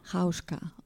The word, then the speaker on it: Hauschka: Volker Bertelmann λοιπόν, γνωστός με το ψευδώνυμο Hauschka, αν το προφέρω σωστά. – Hauschka, okay.